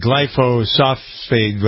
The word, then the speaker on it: glyphosate: So the new one this week, the connection of Lyme disease and glyphosate. I've never pronounced that right. – Glyphosate.